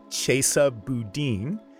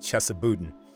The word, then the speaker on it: Chesa Boudin: It's Chesa Boudin, not Chesa Boudin.